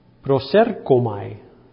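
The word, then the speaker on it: prosercomai (προσέρχομαι): Este término es, se pronuncia en griego algo de esta manera, prosercomai.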